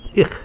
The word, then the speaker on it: 'IH: and our word is ich, spelled apostrophe capital I capital H, ich, beautiful. This letter is pronounced as a glottal stop. It's a brief catch.